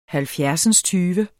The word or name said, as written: halvfjerdsindstyve